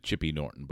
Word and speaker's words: Chipping Norton: They pronounced it Chippy Norton, but it was spelled Chipping Norton.